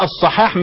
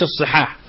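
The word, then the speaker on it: الصَّحاح: هو مش قصده كده، هو قصده في الواقع لا تكسر الصاد يعني من الصحاح، فخليه الصحاح مش الصحاح عشان يبقى نطق صحيح.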